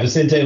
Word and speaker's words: Vicente: Vicente. – Yeah. Vicente Luque.